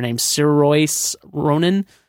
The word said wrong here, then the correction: Saoirse: Oh, it's Shersha.